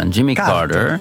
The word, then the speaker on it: Jimmy Carter: Jimmy Carter.